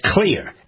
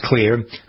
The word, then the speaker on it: Xlear: That's Clear. X-L-E-A-R. Yeah, it's not spelled right, but it's pronounced Clear. The reason it's spelled with an X is because the main ingredient is xylitol, and that begins with an X as well.